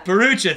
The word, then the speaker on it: Bharoocha: how do you say his last name? – Bharoocha, thank you. Ahmed Bharoocha, Mo Welch, Aaron Weaver, Chris Brinson, Chris Hayes, Gemma Paolo.